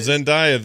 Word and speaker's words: Zendaya: Zendaya? – Oh, Zendaya, the new MJ, because her initials are MJ.